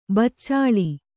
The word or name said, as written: ಬೆಚ್ಚಳ